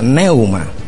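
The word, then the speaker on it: pneuma: P-N-E-U-M-A. Y se pronuncia neuma.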